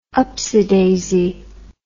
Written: upsidaisy

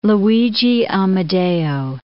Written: Luigi Amedeo

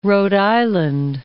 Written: Rhode Island

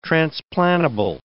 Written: transplantable

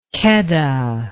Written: kheda

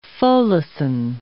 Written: folacin

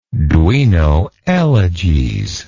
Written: Duino Elegies

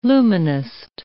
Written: luminist

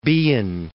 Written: Behan

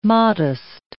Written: modest

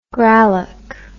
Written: gralloch

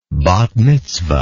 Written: bat mitzvah

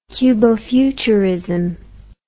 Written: cubo-futurist

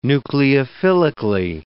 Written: nucleophilically

